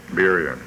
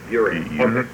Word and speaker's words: Burien: Burien. – Burien.